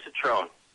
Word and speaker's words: Cetrone: Yes, you got it, Joe Cetrone.